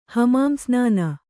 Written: ಹಮಾಮ್‍ಸ್ನಾನ

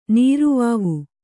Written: ನೀರುವಾವು

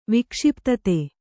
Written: ವಿಕ್ಷಿಪ್ತತೆ

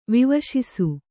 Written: ವಿವಶಿಸು

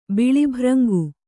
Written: ಬಿಳಿಭ್ರಂಗು

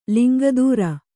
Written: ಲಿಂಗಧರ

